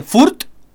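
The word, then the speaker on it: Fürth: Y vive en Furt. ¿Cómo se dice? ¿Furt? Furt.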